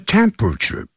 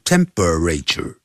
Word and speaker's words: temperature: 量脉搏用 feel 这个字 F-E-E-L. Feel one's pulse. P-U-L-S-E. Take one's temperature. 就这么说的。这个 temperature 不要念成四个音节，不要念成 temperature，叫 temperature。那个 P-E 那个 E 啊是不发音的 temperature，